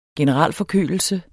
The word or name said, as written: generalforkølelse